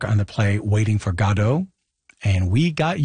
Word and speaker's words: Godot: But we did read that Beckett himself said Waiting for Godot.